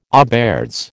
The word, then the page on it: auberge: American English